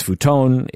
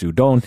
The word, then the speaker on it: Honda: Well, even my name, Honda, that's not how you pronounce it. It's Honda. Honda. – So it's futon, it's, futon. it's, it's udon. Because it's very Latin sounding vowels, which yeah.